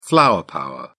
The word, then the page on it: flower power: British English